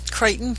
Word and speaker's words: Crichton: Crichton, thank you.